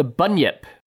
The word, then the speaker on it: bunyip: It's the bunyip.